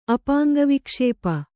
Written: ಅಪಾಂಗವಿಕ್ಷೇಪ

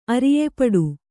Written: ಅರಿಯೆಪಡು

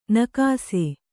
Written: ನಿಕಸ

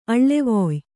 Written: ಅಳ್ಳೆವೊಯ್

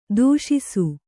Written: ತೋಷಿಸು

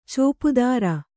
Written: ಚೋಪುದಾರ

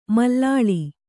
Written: ಮಲ್ಲಲಿ

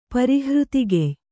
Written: ಪರಿಹೃತಿಗೆಯ್

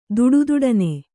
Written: ದುಡುದುಡನೆ

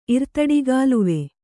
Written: ಇರ್ತಡಿಗಾಲುವೆ

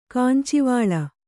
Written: ಕಂಚಿವಾಳ

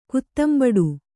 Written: ಕುತ್ತಂಬಡು

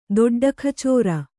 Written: ದೊಡ್ಡಖಚೋರ

